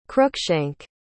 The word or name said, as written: cruikshank